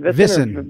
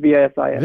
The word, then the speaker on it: VSIN: You know, he looked around, he talked to places, VSN, VSIN. How do you say that? – VSIN. – VSIN. – VSIN. – Yeah, VSIN.